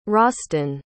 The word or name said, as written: Rosston